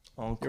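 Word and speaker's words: Ankou: And he posts his picture with Eli Enku, Tyrone Crawford, Chase Claypool. He also tagged Trudeau. I think that's a joke. But, like, and how do you say this? Enku? – Enku. – You're welcome. Enku.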